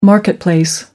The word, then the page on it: marketplace: American English